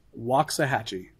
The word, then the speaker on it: Waxahachie: And if you haven't been there yet, you go Waxahachie. But never again, because we were corrected with love. Waxahachie.